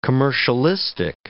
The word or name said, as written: commercialistic